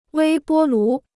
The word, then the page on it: 微波炉: wēi bō lú